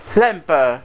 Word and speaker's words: Lemper: En 1963 nace Ruth Limper, que es una cantante alemana. O sea, Ruth Lemper, para ser más justo en la pronunciación.